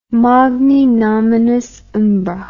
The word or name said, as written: magni nominis umbra